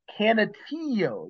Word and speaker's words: Canutillo: Let's move to Division Two, and I'm gonna go ahead and let you take over the this first game, Palo Duro against Cantillo. I can't even pronounce it right. – Canutillo. You got to guess Canutillo. – that Y for the L, the double L's.